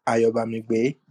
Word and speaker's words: Ayobamigbe: My name is Ayoba Migbe.